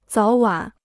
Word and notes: 早晚: zǎo wǎn

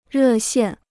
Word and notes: 热线: rè xiàn

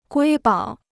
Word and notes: 瑰宝: guī bǎo

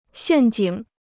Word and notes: 陷阱: xiàn jǐng

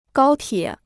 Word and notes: 高铁: gāo tiě

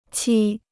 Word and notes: 漆: qī

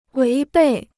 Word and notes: 违背: wéi bèi

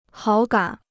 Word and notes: 好感: hǎo gǎn

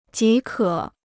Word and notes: 即可: jí kě

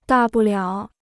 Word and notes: 大不了: dà bù liǎo

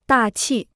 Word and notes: 大气: dà qì

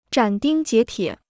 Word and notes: 斩钉截铁: zhǎn dīng jié tiě

